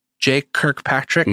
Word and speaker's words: Jay Kirkpatrick: I'm here with Jay Kirkpatrick.